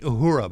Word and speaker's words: Uhura: Uh, it's Lieutenant Uhura.